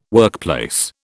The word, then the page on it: workplace: British English